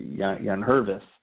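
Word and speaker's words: Yangervis: that it was Jan Gervis. But the G is silent, so it's Jan Hervis Solarte.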